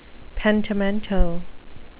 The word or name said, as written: pentimento